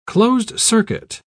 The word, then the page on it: closed-circuit: British English